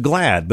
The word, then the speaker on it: GLAAD: The name of the organization is pronounced GLAAD, but it's spelled G-L-A-A-D.